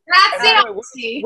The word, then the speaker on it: Graziosi: Graziosi.